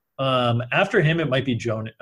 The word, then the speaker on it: Jonnu: Yeah, it's John just Jonnu. It literally is just John with a U at the end of it. – Okay. Jonnu Smith would be the other guy that I would be interested in.